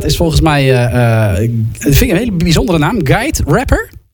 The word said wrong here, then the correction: Gia de Rapper: zeg ik dat goed? – Gia de Rapper.